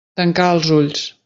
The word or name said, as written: ulls